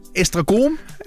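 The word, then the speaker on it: Esztergom: Og så Estragom. Er det nogenlunde korrekt udtalt? – Ja, rigtigt, ja.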